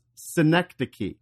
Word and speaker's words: Synecdoche: It's a pun on that, but the word that you said was Synecdoche? – Yeah. – It's pronounced Synecdoche. – Oh, really? – Yeah, which, yeah, which is why it's a pun.